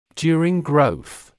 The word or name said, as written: during growth